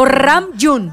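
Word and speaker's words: Ramyun (라면): O bien, a ver si lo estoy pronunciando bien, Ramyun. O Ramyun. – Oh, qué buen coreano. Pues tu coreano está muy bien.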